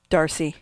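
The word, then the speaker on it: D'Arcy: How do you say this woman's first name? – Darcy. – Okay, it's just spelt weird. D-R-C.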